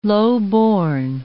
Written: lowborn

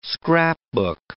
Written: scrapbook

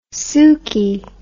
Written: sooky

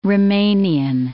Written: Romanian

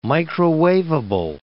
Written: microwaveable

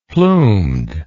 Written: plumed

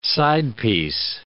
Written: sidepiece

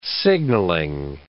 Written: signalling

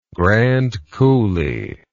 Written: Grand Coulee

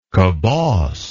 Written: coboss

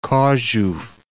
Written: Chorzow

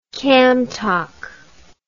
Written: kamtok

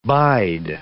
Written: bide

